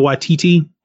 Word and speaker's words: Waititi: Waititi Taika Waititi. – Uh, is that how you say his name? Yeah, uh, yeah.